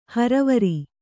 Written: ಹರವರಿ

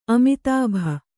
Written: ಅಮಿತಾಭ

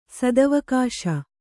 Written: ಸದವಕಾಶ

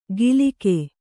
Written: ಗಿಲಿಕೆ

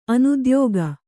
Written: ಅನುದ್ಯೋಗ

